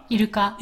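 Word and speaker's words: Iruka (イルカ): How do you say that? Iruka? – Iruka. – Iruka. But Iruka sounds like orca, like English orca.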